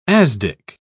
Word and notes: asdic: British English

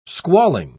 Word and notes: squalling: British English

